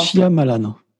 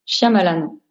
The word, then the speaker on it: Shyamalan: Shyamalan. – Shyamalan.